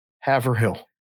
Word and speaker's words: Haverhill: No, H-A-V-E-R. Hover. – H-I-L-L. How do you say that? – H-I-L-L. Haverhill. – Haverhill. – Haverhill? – Haverhill. Yep. – Haverhill? One syllable? – Haverhill. One word. Haverhill. One syllable. Haverhill. Haverhill, Massachusetts.